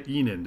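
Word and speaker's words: Ihnen: In fact, he just landed a verbal commitment from Isaiah Enan. Did I pronounce that kid's last name right? – You did, and Enan is German because he's from Germany, and he's coming to the University of Minnesota over the likes of TCU, Stanford, Tennessee.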